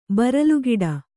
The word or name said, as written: ಬರಲುಗಿಡ